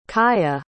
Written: kaia

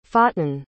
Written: fatin

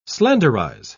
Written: slenderize

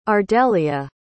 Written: ardelia

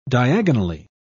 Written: diagonally

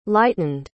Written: lightened

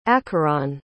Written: acheron

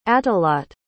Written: atahladte

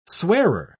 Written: swearer

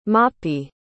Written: mahpee

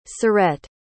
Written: surette

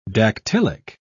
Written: dactylic

